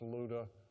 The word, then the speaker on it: Lydda: It looks like Lydda, but it's actually pronounced Luda and Joppa.